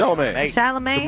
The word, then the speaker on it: Chalamet: Timothée Chalamet, is that how you say it? – Yes. Chalamet.